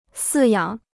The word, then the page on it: 饲养: sì yǎng